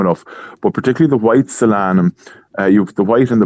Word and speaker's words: Solanum: Okay, Margaret wants to know is, and I hope I'm pronouncing it right, solanum, is it hardy against frost? – Solanum.